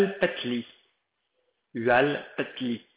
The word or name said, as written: hualpatli